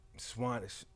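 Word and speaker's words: Suwanee: Greg told me when we first sat down in a hotel in Swanee, did I say that right? – Yes.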